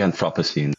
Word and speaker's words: Anthropocene: Und jetzt erscheint deine Promotion im März 2025, und die heißt Reading Lovecraft in the Anthropocene. Habe ich das überhaupt richtig ausgesprochen? – In the Anthropocene.